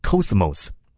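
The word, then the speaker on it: cosmos (κόσμος): 世界这个字希腊文读作 cosmos。 cosmos 这个字在圣经当中有三种不同的意义。第一种意义是世人 cosmos，所以我们读到约翰福音第三章的时候提到神爱 cosmos，什么意思？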